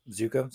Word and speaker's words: Ziuko: Colors by Tom Zuko. I believe that's how you pronounce it. Z-I-U-K-O. – Zuko. Sounds good.